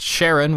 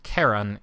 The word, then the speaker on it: Charon: So sometimes you hear it pronounced Charon, which is how the Discoverer pronounced it and how the New Horizons team pronounces it, but if you're going with the classic mythology theme, it'd be pronounced Charon instead.